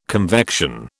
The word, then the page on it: convection: British English